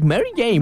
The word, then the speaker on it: Mary Jane: ¡Oh, nomás que no supo pronunciarlo, pero es Mary Jane!